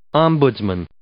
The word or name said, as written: ombudsman